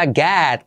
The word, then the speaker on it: get: Fijaos cómo se pronuncia get, casi esa E que tiende a una A, get.